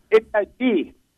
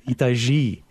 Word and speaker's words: Itajaí: They're leaving from Le Havre, France, and sailing 5,600 miles across both the northern and southern Atlantic, ending up in Itajaí, Brazil. Is that how you pronounce that? – Itajaí. – Oh, you're right. Itajaí, Brazil.